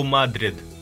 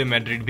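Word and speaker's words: Madrid: Spanish pronunciation में इसको कहते हैं क्लब एथलेटिको ओ माद्रिद इसका मतलब क्लब ऑफ मैड्रिड और इंग्लिश में एथलेटिको दे मैड्रिड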